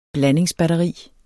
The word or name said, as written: blandingsbatteri